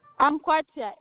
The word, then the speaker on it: Ankwatia: F-R-A-N-K-A. – And what's the last name? – Ankwatia, A-N-K-W-A-T-I-A.